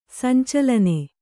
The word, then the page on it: ಸಂಚಾಲನ: sancālana